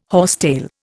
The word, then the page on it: horsetail: Indian